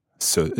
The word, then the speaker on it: ce: It's spelled C E. Ce.